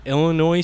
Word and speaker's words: Illinois: But anyways, I last weekend I went fishing out of my boat with my dad, and we checked out the Illinois side. Did I say that right? – Yes.